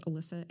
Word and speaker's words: Alisa: My last name is spelled P-Y-S-Z-K-A, and it's Alisa, A-L-I-S-A.